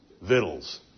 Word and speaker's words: victuals: But when you look it up in the Oxford English Dictionary, which is the most straight-laced formal dictionary of the English language, it tells you to pronounce it just like grandma would, victuals.